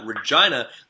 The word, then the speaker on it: Regina: No doubt we'll see you when I visit the series in Regina, the city that rhymes with fun.